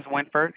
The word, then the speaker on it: Winford: My name is Winford, and what was yours? – Sabino.